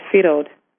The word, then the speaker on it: Ferod: That's Firod. Not Fred. It's Firod. Firod. – How do you spell that? – Firod. F-E-R-O-D. Firod. – Okay, almost a French pronunciation. – Mm-hmm. Firod. – F-R-E-O-D? – Mm-hmm.